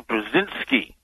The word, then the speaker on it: Brzezinski: Brzezinski.